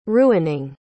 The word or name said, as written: ruining